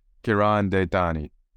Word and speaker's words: Karan Detani: Karan, Detani.